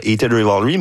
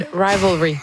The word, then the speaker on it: Heated Rivalry: Et évidemment, on a beaucoup parlé dans la dernière année grâce à la trame sonore de Heated Rivalry, mais... Je l'ai bien prononcé, je pense, c'est la première fois. – Non, Heated Rivalry.